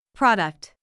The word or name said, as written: product